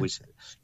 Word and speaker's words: whistle: A ver si lo pronuncio bien. Es el whistle. O whistle. – Whistle, efectivamente.